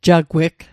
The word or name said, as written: jagweg